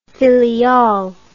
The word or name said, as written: filiale